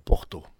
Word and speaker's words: porta: Quand vous avez un mot qui finit par la lettre A, il se prononce O. Et si vous voyez écrit porta, il faut dire porto.